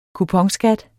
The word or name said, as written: kuponskat